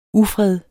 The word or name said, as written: ufred